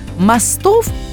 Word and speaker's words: мостов: Наша слушательница Светлана спрашивает, как правильно ставить ударение? Вот проходя мимо этих мостов или мостов.